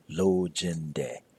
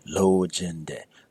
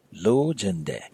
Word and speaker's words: lojende: It should be pronounced as lojende, where all your tones are fairly even. Lojende. Don't let it become lojende. Because lo jende means I want to make love, and not in a very nice way.